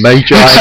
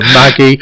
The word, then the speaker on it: Magi: so you'll quite often find us talking about Viz or instead of Weiss, and Magi instead of Maggi.